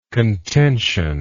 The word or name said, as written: contentional